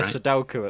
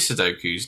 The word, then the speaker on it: Sudoku: I was doing a Sudoku at the time, so I wasn't really... – You do like your Sudokus, don't you? – Again, it's a bit like jelly, really. – It's actually pronounced Sudoku, but...